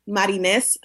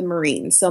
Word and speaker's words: Marines: my handle is at my name is Marines, spelled like the Marine. So my name is Marines.